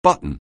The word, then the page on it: button: anglais